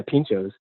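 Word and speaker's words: pintxos: For those that don't know what pintos is, or how did you pronounce that? I totally screwed up. – Yeah, no, that's correct. Yeah, pinchos.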